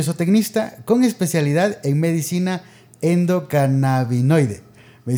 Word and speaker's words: endocannabinoide: Y bueno, sí, está perfecto, se pronuncia medicina endocannabinoide.